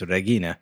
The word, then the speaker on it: Regina: like, we would pronounce Regina, but she says Regina.